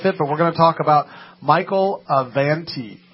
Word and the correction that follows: Avenatti: Is that how you say it? – Avenatti. – Avenatti.